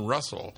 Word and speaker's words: Reustle: This is from Russell, which is spelled R-E-U-S-T-L-E.